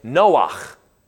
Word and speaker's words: Noach: The guy that built the ark, he had a chet at the end of his name. You would have pronounced his name Noach. Noach is how you would have said that.